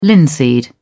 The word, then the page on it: linseed: British English